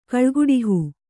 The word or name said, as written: ಕಾಳ್ಗೆಡಹು